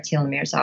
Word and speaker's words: telomere: Telomere is the correct pronunciation. Is that it? – Yes.